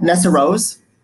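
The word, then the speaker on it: Nessarose: Nessa Rose? That's how you say her name? – Yeah.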